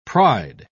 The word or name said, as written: pride